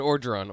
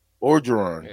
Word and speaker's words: Orgeron: And Orgeron, Orgeron. – Orgeron, yeah.